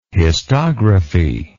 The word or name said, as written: histography